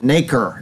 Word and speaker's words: nacre: Researchers developed new glass based on arrangement of the material nacre, is how you would pronounce it, I guess. N-A-C-R-E, nacre.